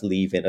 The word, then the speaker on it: Leven: And I think it's pronounced Leaving, as in leaving without a G, released on Cooking Vinyl Records in 2001.